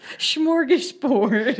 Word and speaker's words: smorgasbord: It's smorgasbord. – Smorgasburg. – No, it's not. – Is. Look. – It's Borg. But it's pronounced Borg. Smorgasbord.